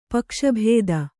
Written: ಪಕ್ಷಭೇದ

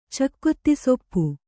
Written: ಚಕ್ಕೊತ್ತಿಸೊಪ್ಪು

